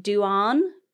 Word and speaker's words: Dubhan: The Irish Saint was the originally was Irish Saint, which is spelled D U B H A N, and it's pronounced Duan Duan.